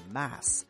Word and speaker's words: mass: 这第一个单词呢，它叫 mess，注意发音上它是一个小口的 a mess。那么对于这个单词，还有另外一个单词，它是大口的 a，叫 mass。